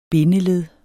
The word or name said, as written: BINDELEDDETS